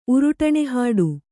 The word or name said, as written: ಉರುಟಣೆಹಾಡು